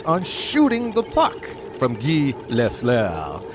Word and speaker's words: Guy Lafleur: Okay, an NHL star, Guy Lafleur, if I'm saying that right. – Close enough.